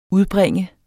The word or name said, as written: udbringe